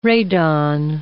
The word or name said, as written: radon